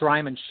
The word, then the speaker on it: Ryman: No, it's Ryman Shelf, like a loaf, like a loaf of bread.